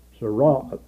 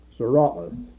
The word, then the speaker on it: tsaraath: And the word which is translated, now that is a word translated leper, but the word translated leprosy in the Hebrew is T-S-A-R-A-A-T-H, and that's pronounced sarah, sarah.